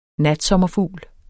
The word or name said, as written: NATSOMMERFUGLE